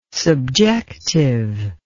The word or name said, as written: subjective